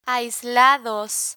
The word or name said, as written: aislados